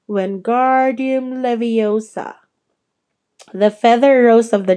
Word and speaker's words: Wingardium Leviosa: Wingardium Leviosa, he shouted, waving his long arms like a windmill. You're saying it wrong, Harry Hermione heard Hermione snap. It's Wingardium Leviosa. Make the gar nice and long you do it then, if you're so clever, Ron snarled.